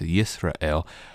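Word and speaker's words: Yisrael (ישראל): El, the ending of the name, so it's actually in Hebrew pronounced Yisrael, the El is God.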